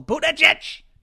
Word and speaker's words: Buttigieg: Buttigieg! It's Buttigieg. Pete Buttigieg. You've got to say boot, then edge again.